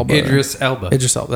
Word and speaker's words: Idris Elba: Alba. – Yeah, I don't know how to say his name. – Idris Elba.